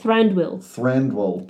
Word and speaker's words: Thranduil: It's T-H-R-A-N-D... Yeah. – What did you say? – I don't know. – Thranduil. Thank you. I was like, excuse me, what is this word you're saying? – He's also...